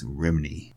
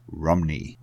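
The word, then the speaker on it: Rhymney: Both sing it as Rimini. Heck, that's how we all say it, right? Well, not the Welsh. They pronounce it as Rumney, which sounds a lot like Romney, as in Mitt Romney.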